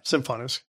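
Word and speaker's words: Symfonisk: So Sonos and IKEA have not given up their partnership after releasing is it Symphonisk? Is that how you pronounce this? – Yeah, Symphonisk.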